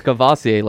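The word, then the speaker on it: Kavosiey: It's Cavassier, like the alcohol.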